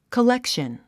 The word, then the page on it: collection: kəlékʃən; コレクション